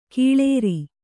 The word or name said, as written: ಕೊಳ್ಳಾರ